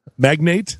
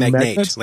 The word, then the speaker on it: magnate: Do you pronounce this differently? – Magnate? – You say magnate, like potentate.